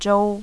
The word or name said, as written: zhou